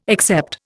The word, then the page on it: except: Indian